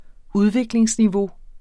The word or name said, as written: udviklingsniveau